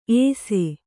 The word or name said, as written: ಏಸೆ